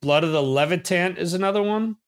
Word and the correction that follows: Levant: I don't know if I'm pronouncing that correctly. – Blood of the Levant. Yeah. I mean, that's my favorite track as well, really. – Yeah. Blood of the Levant. Okay. You're right.